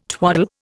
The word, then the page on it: twaddle: Indian